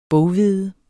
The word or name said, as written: boghvede